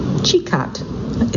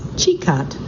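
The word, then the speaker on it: Čikat: The jewel of Losange, and by the way, that's spelled L-O-S-I-N-J, is Sikat, Chikat. It's nice, he's giving me pronunciations as I go. So the jewel of Losange is Chikat, C-I-K-A-T, Bay,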